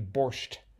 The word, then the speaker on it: Borscht: I was thinking like Borsch belt with an E, but it's actually Borscht. – Yeah. With the T with the T mm-hmm.